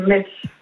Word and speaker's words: Metz: Ah, alors, tiens, demande si elle prononce Metz aussi. – Metz, Metz, Metz ou Metz? – La ville. C'est Metz.